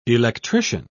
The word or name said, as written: electrician